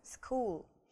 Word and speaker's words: school: Итак, запомнили, что school читается со звуком К. Даже несмотря на то, что здесь сочетание CH, мы его не читаем как Ч, мы его читаем как К, school.